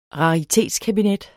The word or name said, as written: RARITETSKABINET